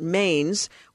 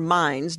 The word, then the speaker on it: Mainz: First, there is the young bishop, too young by church laws, Albert Mainz, or Mines, depending on your pronunciation.